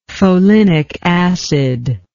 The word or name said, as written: folinic acid